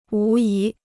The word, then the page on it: 无疑: wú yí